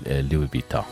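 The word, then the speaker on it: Louis Vuitton: أيضا عندي أيضا شيخة شكرا على المسج تقول ماركت لوي بيتان, هو فعلا مثل ما ذكرت أحيانا تنطق, يعني البعض يعني ينطق الأس, البعض يقول لوي بيتان.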